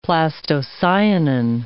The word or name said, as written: plastocyanin